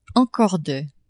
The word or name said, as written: encore de